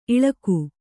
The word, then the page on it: ಇಳಿಕು: iḷiku